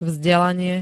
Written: vzdelanie

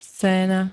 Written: scéna

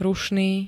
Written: rušný

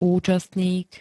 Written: účastník

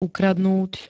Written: ukradnúť

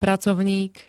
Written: pracovník

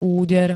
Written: úder

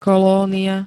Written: kolónia

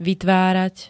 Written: vytvárať

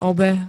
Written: obeh

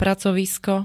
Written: pracovisko

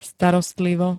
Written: starostlivo

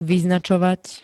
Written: vyznačovať

